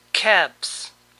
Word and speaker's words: calves: Calves. Como ven, la L no se pronuncia.